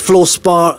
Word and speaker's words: fluorspar: Okay, so you say, and you are the only UK listed company of fluorspar. Am I saying that right? Is it fluorspar? Is that right? – Fluorspar, that's right. Yeah.